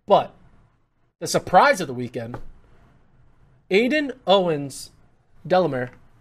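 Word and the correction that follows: Delerme: Did I say his name right? Delamer? – Delarmie. – Yep. Thank you.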